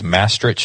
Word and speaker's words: Maastricht: He's a professor of biochemistry at the University of, I think, Maastricht. That's how you pronounce it, in the Netherlands.